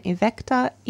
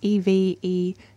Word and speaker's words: evecta: Oh, yes. Pteris. So Angiopteris. Angiopteris. And then evecta, evecta.